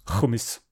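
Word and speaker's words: Hummes: It's spelled Humes, but apparently it's supposed to be pronounced Humis.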